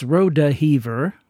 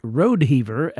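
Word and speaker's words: Rodeheaver: That's Rodehever with four syllables and not Rodehever as it's spelled.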